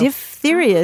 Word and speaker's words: diphtheria: Diphtheria is the way you pronounce it, because part of the characteristics of that disease is that you get sort of a leathery membrane in your throat.